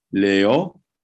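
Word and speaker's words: Liù: And her name is Liu, L-I-U with an accent grave over it. – Right. – And it's not to be pronounced as a glide, like in Italian, sometimes they'd say Liu. But it's two syllables. And actually in Chinese, I looked this up because I don't know anything about Chinese, mm-hmm. but they say Leo.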